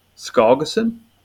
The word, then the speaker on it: Skjalgsson: but Erling Skogason? – Erling Skogason is how you pronounce Skogason. It. You did pretty well.